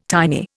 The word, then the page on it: tiny: Indian